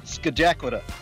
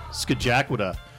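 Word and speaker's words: Scajaquada: You know you're from Buffalo when you not only know what a bathtub Madonna is, but you can also correctly pronounce the Skagitwade Expressway. Which he's shaking, shaking his head, so I don't think I pronounced it correctly. – Skajakwada. – Skajakwada. – There you go.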